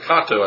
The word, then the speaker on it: kato: Greek form kato. It's actually kato, I think, the way it's pronounced.